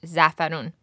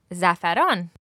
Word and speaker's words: zafaran: So when you see Persian rice with that rich yellow-orange color, it's because it's been mixed with zafarun. So again, zafarun. – Zafarun. – And it can also, of course, be pronounced zafaran. – Zafaran. – And you can see that's where the word saffron comes from.